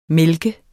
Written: mælke

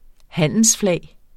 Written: handelsflag

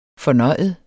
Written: fornøjet

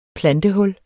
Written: plantehul

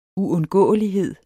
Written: uundgåelighed

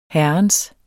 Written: herrens

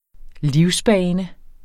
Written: livsbane

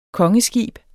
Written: KONGESKIB

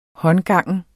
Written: håndgangen

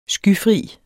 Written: skyfri

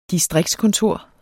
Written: distriktskontor

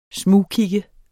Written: smugkig

